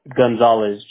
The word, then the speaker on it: Gonzalez: For the account information here with me, your first name, do you spell it L-E-E? – Yes. – Okay. And Lee, what is your last name? – Gonzalez, G-O-N. Z-A-L-E-Z.